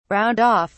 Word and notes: round off: British English

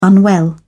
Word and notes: unwell: British English

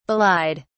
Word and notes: belied: British English

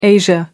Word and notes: asia: American English

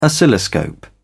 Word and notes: oscilloscope: British English

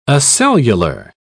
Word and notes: acellular: British English

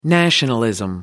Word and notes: nationalism: American English

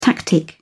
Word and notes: tactic: British English

